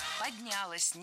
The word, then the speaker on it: поднялось: Как это предприятие поднялось? Как там развилось? Когда его двигали там с одного предприятия на комплекс предприятий? – Поднялось неверно. Правильно, поднялось. Ударяем окончание.